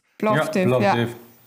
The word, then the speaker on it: Plovdiv: Plovdiv, spricht man das aus? – Plovdiv.